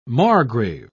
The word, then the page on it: margrave: British English